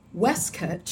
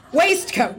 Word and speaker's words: waistcoat: This formal day attire consists of a black or dark Oxford gray cutaway coat, usually with a matching waistcoat, pronounced waistcoat. – Shut up. – Not waistcoat.